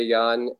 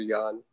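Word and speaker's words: Jahn: Yan, and find my Facebook page under the name Andrew Yan.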